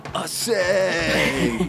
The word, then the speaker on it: Ossë: Ossë I think he'd say it like this. Ossë, dude! – You've nailed it, because Ossë is without a doubt the god of surfing.